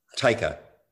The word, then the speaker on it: Taika: To Taika.